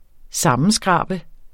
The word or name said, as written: sammenskrabe